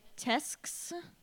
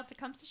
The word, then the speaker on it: Tecumseh: Well, how do you pronounce General Sherman's first name? Like, Tesks? – Spell it for me. – T-E-C-U-M-S-E-H. – Tecumseh. – Yep, there we go.